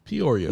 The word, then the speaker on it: Peoria: Peoria, Illinois. Did I say that right? – Peoria. Nobody goes there. That's cool. – Peoria. – Decatur. – Decatur was good.